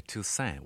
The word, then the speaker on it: Toussaint: My father used to say Toussaint without a T on the end.